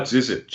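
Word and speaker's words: Žižić: About Zizic, should I say that correctly? – Yeah.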